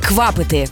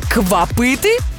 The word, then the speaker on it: квапити: Наголос, друзі, на першому складі «квапити». Бо якщо сказати «квапити», то це вже звучатиме ніби нова назва енергетика.